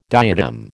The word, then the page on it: diadem: American English